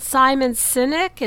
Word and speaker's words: Simon Sinek: So I'm guessing you really like, is it Simon Sinek? Is that how you pronounce his name? – Oh, that is how you pronounce his name.